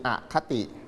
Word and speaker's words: อคติ: ในปัจจุบันสังคมมีคนโหดร้ายไหมมีคนโหดเหี้ยมไหมมีคุณช้างเป็นตัวละครในสมัยก่อนก็โหดเหี้ยมโหดร้ายเหมือนกันก็นี่แหละเหมือนคนจริงๆนะครับต่อมาข้อที่3เขาบอกว่าถ้าอ่านเรื่องนี้อย่างไม่มีอคติอ่านว่าอคตินะครับ